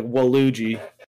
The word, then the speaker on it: Waluigi: do you know how like word letters after like a vowel make it like a long A? – Yeah. – The U after the L makes the A long, so it's like Waluigi.